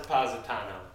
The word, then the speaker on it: Positano: No, I don't want to get pronounced Positano.